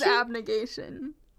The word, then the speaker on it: Abnegation: Abnation? – Yeah. – Is that how you say it? There's a G in there. Is it silent? – I don't know. – Abnegation? – I think it is abnegation.